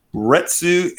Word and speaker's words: Retsu: So Retsu and Geki are computer-controlled opponents in which video game? – What were their names again?